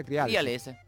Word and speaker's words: Crialese: Compositor de la banda sonora del film Nuevo Mundo, di Emanuel Calce, ¿cómo se pronuncia? – Crialese.